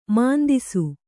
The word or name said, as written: ಮುಂದೆಸೆ